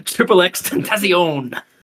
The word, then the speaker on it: XXXTentacion: Are we sold on that pronunciation? – Tentacion? – Yeah, okay. – Triple X Tentacion.